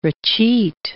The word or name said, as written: recheat